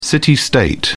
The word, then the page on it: city state: British English